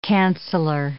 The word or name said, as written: canceller